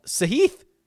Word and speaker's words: Sahith: Sahith, I believe is how he actually pronounces it.